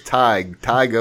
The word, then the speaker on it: Tadhg: His name is spelled T-A-D-H-G. And he was cracking up laughing. He said, man, this is hilarious. So he sent me a pronunciation for his name. It's Tige O'Connor.